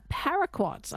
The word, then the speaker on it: paraquat: We are going to talk, though, about a herbicide, paraquat.